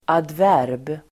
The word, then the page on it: adverb: [adv'är:b]